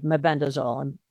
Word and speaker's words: mebendazole: So the most recent evidence of the effectiveness of ivermectin and mebendazole, and that is similar to things like over-the-counter fenbendazole. Now, people pronounce mebendazole incorrectly. There's no M-E-N. It's M-E-B-E-N-D-A-Z-O-L-E.